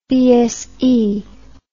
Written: BSE

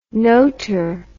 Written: notour

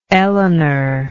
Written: Elenore

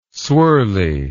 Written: swirly

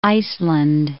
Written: Iceland

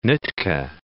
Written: Nootka Sound

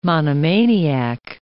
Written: monomaniac

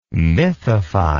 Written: mythify

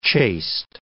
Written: chaste

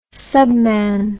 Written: subman

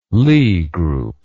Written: lie group

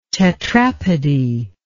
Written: tetrapody

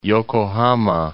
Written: Yokohama

